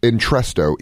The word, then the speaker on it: Entresto: How do you pronounce that? – Entresto. E-N-T-R-E-S-T-O. – Entresto, okay.